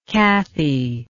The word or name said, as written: cathie